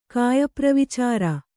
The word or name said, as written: ಕಾಯಪ್ರವಿಚಾರ